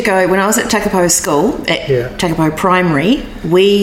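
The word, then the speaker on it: Takapau: I'm a Takapo girl. – Yeah, yeah. – So there you go. Or Takapo, as I should say. – Yeah, yeah. – Correct pronunciation. My apologies.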